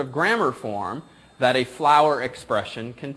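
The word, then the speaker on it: FLWOR: It's a flower expression.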